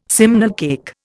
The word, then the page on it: simnel cake: Indian